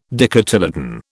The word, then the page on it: dicotyledon: British English